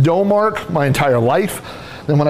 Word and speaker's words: Domark: I found someone online who said it is Dumark.